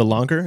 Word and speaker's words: langar: The langar, is that how you pronounce it? – Yep.